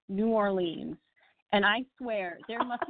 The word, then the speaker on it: New Orleans: Well, the other burning question I have is because I've never been to New Orleans, and I don't know how to pronounce it. Is that New Orleans? Is that, did I say it right? – You actually did it really, really well.